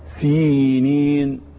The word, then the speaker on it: سينين: خلاص الصحيح سينين.